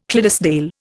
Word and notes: Clydesdale: Indian

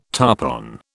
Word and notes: tarpon: British English

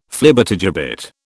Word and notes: Flibbertigibbet: British English

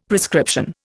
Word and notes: prescription: Indian

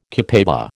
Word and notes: copaiba: American English

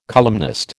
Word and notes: columnist: American English

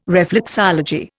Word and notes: reflexology: Indian

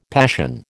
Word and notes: passion: American English

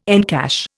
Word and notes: encash: Indian

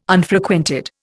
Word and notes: Unfrequented: Indian

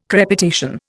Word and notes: crepitation: Indian

